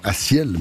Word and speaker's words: A6EL: Il vient de Renan, il a 20 ans, il faut prononcer son nom Assiel, même s'il s'écrit A6EL.